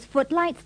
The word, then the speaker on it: footlights: Don't pronounce the GH in footlights and spotlight. – Fix those footlights, Thomas.